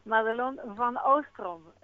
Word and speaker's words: Madelon van Oostrom: Madelon van Oostrom. Es holandés, pero no espero que nadie lo pronuncie así, la verdad.